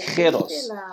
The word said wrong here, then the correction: giros: jeros, jeros. – Giros.